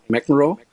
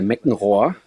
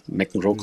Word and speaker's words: McEnroe: Und ich habe gesagt, der Meckenrohr, so habe ich den, also McEnroe konnte ich noch nicht aussprechen. Meckenrohr habe ich gesagt, der wird mal Nummer eins der Welt.